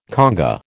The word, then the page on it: conga: American English